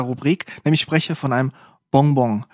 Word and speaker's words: Bonbon: Das ist nicht einfach für mich auszusprechen, weil ich will es einmal auf Französisch aussprechen. Bonbon. Auf Schweizerdeutsch, Daffeli, Sugus, Zartli. Je nach Region. Bonbon. Bonbon. Also wie eigentlich die Bon, aber einfach zweimal.